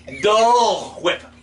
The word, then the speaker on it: Dole Whip: Dale Whip. No, it's Dull Whip.